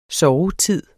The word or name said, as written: sovetid